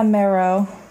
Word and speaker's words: Mero: Is that how you say your name? It's not Miro or... – Tessa Mero.